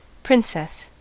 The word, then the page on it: princess: American English